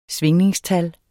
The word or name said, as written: svingningstal